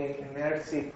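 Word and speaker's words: Immirzi: And it's called the Immerzi parameter, just to show that we all have our Italian pronunciation. And we like Giorgio Immerzi, who was the person who introduced it.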